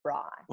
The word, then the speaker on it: braai: I'm sorry, you've got to be able to pronounce braai.